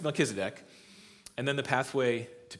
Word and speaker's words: Melchizedek: Then we're going to see about Melchizedek. If you want to know how to say it, I had to read this many, many times. Melchizedek.